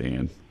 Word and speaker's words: Ayn: I don't know how you pronounce it. It's I'm going to go with Ayn Rand, A-Y-N Rand. – Yeah, my professor's always just said Ayn.